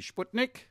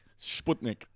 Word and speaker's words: Sputnik: And the Russian pronunciation, which is where it comes from, would be Sputnik. – Sputnik.